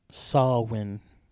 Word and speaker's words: Samhain: Or Samhain, as it's actually pronounced.